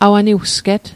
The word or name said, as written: awaniwsget